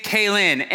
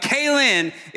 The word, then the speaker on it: Keighlynn: Okay, so our student today, thank you, is named Kaylin, and it's spelled K-E-I-G-H-L-Y-N-N, if you were wondering. But Kaylin is a 7th grader here in GJ. K as in like nay, N-E-I-G-H, but K, yeah, anyway.